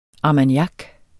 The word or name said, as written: armagnac